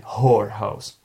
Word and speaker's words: whorehouse: But be careful because WH letter at the beginning of this word is silent. So you have to say whorehouse. Whore. Yeah, not whore. Whorehouse. Okay, it's like who or whose.